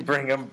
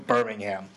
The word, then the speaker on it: Birmingham: Pronouncing Brigham, Birmingham.